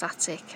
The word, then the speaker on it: Atwick: Well, it's spelled Atwick, but it's pronounced Attic as far as I know.